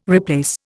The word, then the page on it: replace: Indian